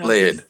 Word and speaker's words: Laird: Is it Laird or Lard? I'm not sure Laird. How to say his name, but no, Laird. – Laird, yeah.